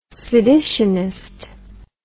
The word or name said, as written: seditionist